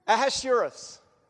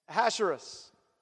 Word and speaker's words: Ahasuerus: Ahasuerus. Okay? Ahasuerus.